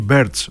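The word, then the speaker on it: Birch: Και ο Κέμ Μπίρτς ή Μπέρτς, όπως το προφέρουν έτσι οι πιο γνώστες, άλλους 6 πόντους.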